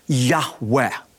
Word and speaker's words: Yahweh: feel is pronounced a breathy Yahweh.